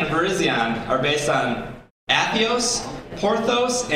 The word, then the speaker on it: Virizion: So some of you guys said it right. It's Cavalion, Terrakion, Virizion, and Keldeo.